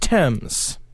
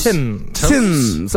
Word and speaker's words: Thames: I believe it's pronounced Thames. – Thames? – Is it Thames? – Thames. Okay.